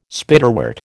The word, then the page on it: Spiderwort: American English